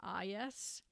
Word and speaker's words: eyas: The first chick, and the technical term for a hawk chick or a hawk baby is an IS. It's spelled E-Y-A-S, pronounced IS.